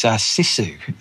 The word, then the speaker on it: Sisu: Well, I'll just start, Andy, by your pronunciation. It's Sisu. So Sisu is it's a Finnish word, actually.